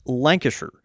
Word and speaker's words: Lancashire: It's the same with Lancashire. It's pronounced Lancashire.